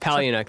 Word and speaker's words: Palahniuk: Palahniuk, there we go. I can never say it right.